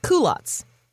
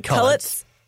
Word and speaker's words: culottes: Like culottes. – Oops, that's how it's pronounced. – Oh, did you say cullets? – Yeah.